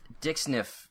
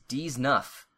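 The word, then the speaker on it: dix-neuf: You don't pronounce dick sniff right in the Divestor episode. It's French for 19. It's pronounced D's nuff.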